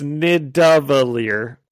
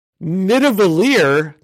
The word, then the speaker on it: Nidavellir: He says the V instead of the D. It's Nidavellir. And he says, I have to go to Nidavellir. And then Rocket, immediately after, is like, Nidavellir? That's a real place? Saying it correctly.